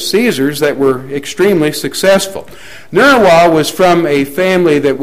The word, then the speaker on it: Nerva: Now, I'm saying Nerva because the Latin V is pronounced like a W. You can say Nerva if you want to, and I wouldn't know if either one of us is really pronouncing it right.